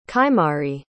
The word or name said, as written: kaymari